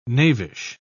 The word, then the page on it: knavish: British English